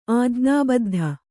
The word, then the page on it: ಆಜ್ಞಾಬದ್ಧ: Kannada